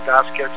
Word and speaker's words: Vasquez: But if you log on to under my name, you know, just look for me on the internet under Edwin Vasquez Musica. And that's Vasquez with two Z's, V-A-Z-Q-E-Z. Sometimes it's spelled V-A-S, that's my case now.